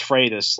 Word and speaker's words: Freitas: It's pronounced Freitas, like Frito, like Freitas. – Like yeah. – Freight train. – Like freight train. And then us.